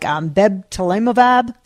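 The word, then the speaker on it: bebtelovimab: I'm going to say it wrong, like bebtelemovab, something like that. – Wow, you did fantastic. – Was I close? – You did exactly right.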